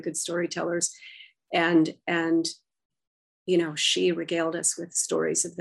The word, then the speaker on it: sidhe: She is the Irish word for fairy. It's spelled S-I-D-H-E.